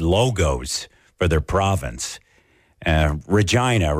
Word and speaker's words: Regina: people say regina is the city that rhymes with fun so the tourism board took advantage of that and mm.